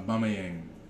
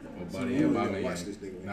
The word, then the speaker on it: Aubameyang: Aubameyang. – Sounds right. It sounds... – Nah, I know. – It sounds excellent. – That's it, man. Aubameyang.